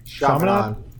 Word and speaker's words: Charlemagne: And then, what's the Charlemagne Guard, I think? Is that how you say it? – Who? – Charlemagne. – Yeah, there you go.